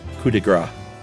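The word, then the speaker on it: coup de grâce: The Arctic Fox episode is the latest, and Josh said the phrase coup de grace, where he correctly left the P silent, but incorrectly said fat.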